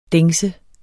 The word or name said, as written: dengse